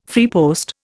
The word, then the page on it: freepost: Indian